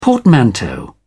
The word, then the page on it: portmanteau: British English